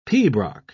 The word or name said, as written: pibroch